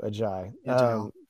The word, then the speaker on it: Agiye: I think it's Ajayi. – Thank you, Ajayi.